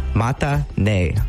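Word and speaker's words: mata ne (またね): It's pronounced mata, M-A-T-A, mata, and then ne, N-E, mata ne, mata ne.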